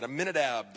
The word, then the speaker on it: Amminadab: Amminadab, the father of Nashon.